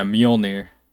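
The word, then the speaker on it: Mjölnir: yeah, Mjolnir.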